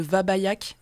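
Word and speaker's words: Wabbajack: Et du coup, Wabajack, c'est un bâton de mage dans mon jeu vidéo préféré qui s'appelle le Vabayak à la base, mais ça s'écrit pareil, et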